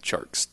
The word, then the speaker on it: Tjarks: And then he, uh, yeah, it is Jonathan Tjarks, T J A R K S.